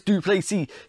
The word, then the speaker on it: du Plessis: Dricus du Plessis, he is a phenom from South Africa. The S is silent, and always he looks tired, but he's big, he's strong, he's good in every area.